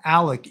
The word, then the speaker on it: Alex: His name is pronounced Alec, even though it's spelled A-L-E-X, which would be Alex.